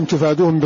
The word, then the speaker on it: تفادوهم: فقرأ نافع والكسائي وعاصم تفادوهم بضم التاء وفتح الفاء وألف بعدها, وهو مراده بالمد, والمد أي بعد الفاء بعد الفتحة, والمد أي بعد الفاء تفادوهم, وليس له محل إلا هذا.